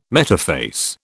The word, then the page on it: metaphase: British English